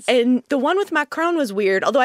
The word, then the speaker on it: Macron: Am I saying that right? Macron. – Nice.